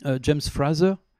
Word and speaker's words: Frazer: Mais il se trouve que James Frazer, c'est comme ça qu'on prononce,